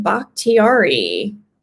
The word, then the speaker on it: Bakhtiari: David Bakhtiari. – We worked on that so much pre-show. – We did. We did actually have the pronunciation right, but you got to just double check.